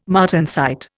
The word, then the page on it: martensite: Indian